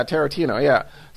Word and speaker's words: Tarantino: Tarantino. – Yeah, Tarantino.